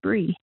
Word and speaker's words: Bree: Growing up, my name was often mispronounced as Brianna, so I got in the habit of telling people they could call me Bree. But because that rhymed with a lot, sometimes people misunderstood what I said, so I started adding "like the cheese" for clarification.